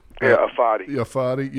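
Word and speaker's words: Ifeadi: Fetty Odenebo. Is that how you say it? – Afadi. – Yeah, Afadi Odenebo.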